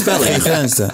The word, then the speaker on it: Kristianstad: But it's spelled Kristianstad. – Oh, it's spelled Kristianstad. – Yeah. But it's pronounced Kristianstad.